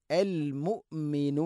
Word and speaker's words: المؤمن: അൽ ഖുദ്ദൂസു സലാം അസ്സലാമുൽ മുഅ്മിൻ അസ്സലാമുൽ മുഅ്മിനു അൽ മുഅ്മിനു المؤمن المهيمن المؤمن مؤمن الا المؤمن ഹംസയുടെ ഉച്ചാരണം കൃത്യമാക്കണം. തൊണ്ടയുടെ താഴെ ഭാഗമാണ്. എന്നാൽ ഖൽഖല വരാൻ പാടില്ല. അൽ മുഅ്മിനു അല്ല, അൽ മുഅ്മിനു. മുഅ്മിനു എന്ന് ഹംസ തീരെ ഉച്ചരിക്കാതിരിക്കാനും പാടില്ല.